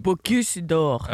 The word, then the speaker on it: Bocuse d'Or: Da skal vi starte med en sak som handler om noe du må hjelpe med å uttale. Dine kokkekonkurransen Bocuse d'Or. – Åh, jeg vil sagt... – Bocuse d'Or. – Ja. – Bocuse d'Or, kanskje, ja. – Akkurat sånn, ville jeg sagt det.